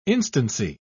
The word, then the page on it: instancy: British English